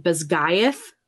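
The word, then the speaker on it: Basgiath: Bazgith, I think was like the school. – Mm-hmm. – But apparently she pronounces it Bazgaith College, War College or whatever.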